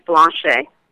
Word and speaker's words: Blanchet: The last name is Blanchet.